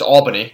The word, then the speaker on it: Albany: It's Albany, not Albany. – It's the Canadian in me, you know. – Yeah, you know, I won't hold it against you, but in the future we'll stick to Albany, and you know, I'm sure some of the boys at UMass will like that one.